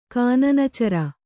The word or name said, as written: ಕಾನನಚರ